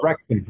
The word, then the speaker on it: Wrexham: It's Wrexham. Wrexham's his team. – Wrexham. – Yeah, that's right. Damn near we decided that, didn't we? – Because it rhymes with rectum.